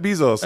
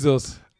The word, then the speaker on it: Bezos: Jeff Bezos. – Hä? Amazon Prime Gründer? – Ach, Bezos. – Ja, okay. – Bezos ist in Ami ausgesprochen.